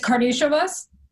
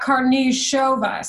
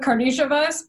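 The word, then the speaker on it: Karnišovas: So like if you go by that theory, then it's Karnisovas. And not Karnisovas. So I'm going with Karnisovas,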